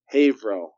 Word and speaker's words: Haverhill: Haverhill. – Haverhill. – You just kind of just smash the three letters in the middle together. – Yeah, Haverhill. – Okay, Haverhill. All right. So Haverhill, Massachusetts is where Dr. Duncan McDougall was doing his research, and that is where the comment in the intro comes from.